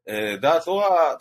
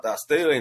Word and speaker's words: Da'at Torah: Da'at Torah, Da'as Torah in the Haredi pronunciation,